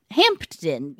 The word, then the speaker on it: Hampden: But it's spelled Hampden, like H-A-M-P-D-E-N, but I did Google it, and it is pronounced Hampden, ?